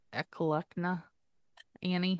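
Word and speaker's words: Eklutna: And you know, if they can figure out who the, what is it, a clunt, man, I have to actually say it wrong. – Eklukna Annie.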